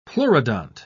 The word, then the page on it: pleurodont: British English